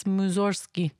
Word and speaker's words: Mussorgsky: It's spelled Mussorgsky, but you don't really say the G, you know? It's Mussorgsky because it's Russian.